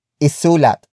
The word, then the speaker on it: isulat: For example, isulat, isulat, isulat. Isulat is spelled as I-S-U-L-A-T.